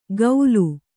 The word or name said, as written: ಗೌಲು